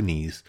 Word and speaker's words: knee: which is to get down on two knees. And wonderful English, that is spelled K-N-E-E, knee. The K is silent.